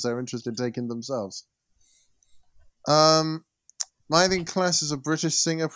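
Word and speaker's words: Myleene Klass: Is it Mylene Klass? – Yeah, that's it.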